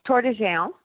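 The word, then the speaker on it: Tor des Géants: What is the correct Italian pronunciation of this race? – It's Tour de Gence. So it's a French pronunciation, really, because Aosta Valley, where the race is being held, has a lot of French speakers in it. So a lot of the names actually sound French, not Italian.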